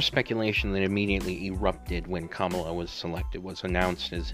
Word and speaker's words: Kamala: And he selected, and I hope I'm pronouncing her first name right, Kamala Harris. Not Kamala, Kamala.